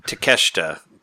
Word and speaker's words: Takeshita: I'm sure you, as your friends, you know, whenever you saw the name in a video game credit that said like Takeshita, like take Sheeta, right? – You pronounce that Takeshita because there's a wrestler named Takeshita, and I just learned that from wrestling.